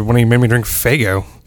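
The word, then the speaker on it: Faygo: Sorry, it's pronounced, it's pronounced Fago. – My mistake. It was a umlaut in there. I didn't. – This is a hard F. Very hard.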